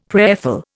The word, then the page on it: prayerful: Indian